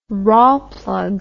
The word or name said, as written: rawlplug